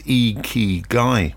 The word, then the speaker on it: Ikigai: Ikigai, I-K-I-G-A-I, as I said, pronounced Ikigai, is a word you hear a lot in Japan.